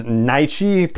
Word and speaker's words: Nyche: However, the actress who plays her has said on social media that that character's name is Nyche, pronunciation, N-Y-C-H-E.